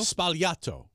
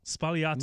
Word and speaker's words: Sbagliato: So how do you say that? Sbagliato? – Sbagliato. – Sbagliato. – Negroni Sbagliato. – Huh.